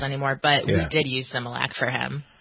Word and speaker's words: Similac: Is it Similac? I've seen this. I don't know how to pronounce Similac.